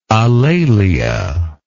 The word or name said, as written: alalia